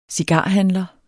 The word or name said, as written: cigarhandler